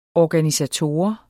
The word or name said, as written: ORGANISATOR